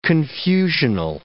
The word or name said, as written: confusional